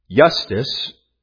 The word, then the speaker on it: Justus: Justus, which it begins with an I, is pronounced with a Y, but yet corresponds to our J, refers to being just or righteous.